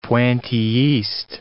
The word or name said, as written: pointillist